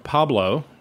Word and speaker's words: Pableaux: I spell my name, Pablo, spelled in the South Louisiana fashion, or the imaginary South Louisiana fashion. It is P-A-B-L-E-A-U-X.